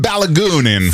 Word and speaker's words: Balogunin: Eli Donald on soittanut mitä ilmeisimmin FIFAn johtoon tästä Yhdysvaltain Folarin Balu, miten tämä lausutaan? Palogunni. Folarin Palogunni. No niin, amerikkalainen. Folarin Palogunni. – Folarin Balogunin.